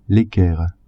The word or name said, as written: equerre